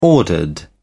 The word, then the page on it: ordered: British English